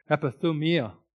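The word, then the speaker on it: epithumia (ἐπιθυμία): In fact, when he says that he desired to go to heaven, he uses a Greek word that is pronounced epithumia.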